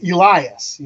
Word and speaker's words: Elias: I mean, I yeah, teachers or every year, every year in school, the teacher would mispronounce my name, Ellie, Eli, Elias. you know, my because my my full name is Elias. You know, E L I A S.